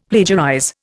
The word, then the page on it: plagiarize: Indian